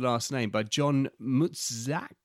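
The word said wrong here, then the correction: Matuszak: Matuzak. – It's a bit like Korchak.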